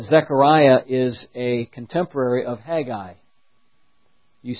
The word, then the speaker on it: Haggai: You'll see in Haggai, some people call it Haggai, but actually there's just Haggai, those two syllables.